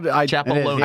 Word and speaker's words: Chapaloni: H-E-S-B-Y. – Perfect. – Street. – And then Zack. – Zack Chapaloni. Yeah. – It's spelled how it sounds. – It's spelled. – Chapaloni.